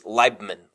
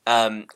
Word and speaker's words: Leibman: It's Leibman, long I sound. Leibman, Leibman.